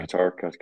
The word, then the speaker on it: Qatar: Yeah, Qatar, yeah.